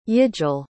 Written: yigil

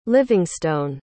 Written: livingstone

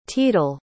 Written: teedle

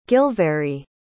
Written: gilvarry

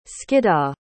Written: Skiddaw

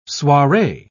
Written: soiree

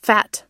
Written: phat

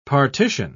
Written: partition

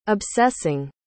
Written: obsessing

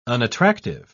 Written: unattractive

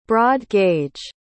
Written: broad-gauge

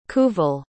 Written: kuval

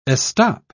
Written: estop

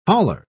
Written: holler

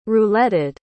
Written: rouletted

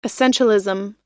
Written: essentialism

